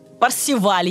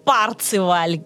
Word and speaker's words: Парсифаль: Что за Парсифаль? Парсиваль его называют, Парциваль, э, Парсифаль. То есть э, там э, ударение, на самом деле, куда захочешь, туда и ставь.